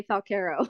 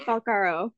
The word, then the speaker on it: Falcaro: It is actually the correct way to pronounce it, although I will say Falcaro. But it's supposed to be Falcaro.